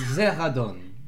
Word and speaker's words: Zeradon: My name is Zeradon. Say it like that. Zeradon.